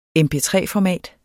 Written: MP3-format

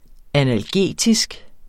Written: analgetisk

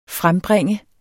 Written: frembringe

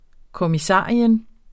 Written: kommissarius

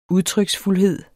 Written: udtryksfuldhed

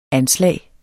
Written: anslag

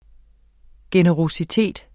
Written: generøsitet

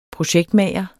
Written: projektmager

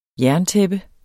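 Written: JERNTÆPPE

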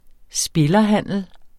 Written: spillerhandel

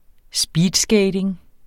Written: speedskating

